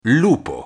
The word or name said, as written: lupo